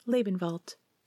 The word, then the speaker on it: Lebenwald: That's pronounced Lebenwald. Go ahead and say it. German is fun.